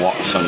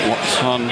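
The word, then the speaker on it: Wouxun: The Baofeng, Watson. Am I pronouncing those right? – I think you got them both right. Usan, maybe. I'm not sure.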